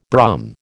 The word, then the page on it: Brum: American English